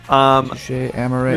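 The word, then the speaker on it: Touché Amoré: And isn't it Touche Amour? There is an accent there. – Yeah. I like pronouncing things correctly. – Sure. – Touche Amour.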